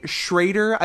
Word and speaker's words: Schroeder: Could be Schrader. I've seen S-C-H-R-O-E-D-E-R pronounced Schrader sometimes, yep. Which I don't care for because there's no A involved.